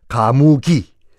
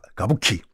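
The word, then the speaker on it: 가부키 (歌舞伎): 우리 발음으로 하면 가무기. 일본식 발음은 가, 가부키.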